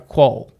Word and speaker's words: quoll: A quoll. – Quoll? – Yeah, I think that's how you say it. Q-U-O-L-L.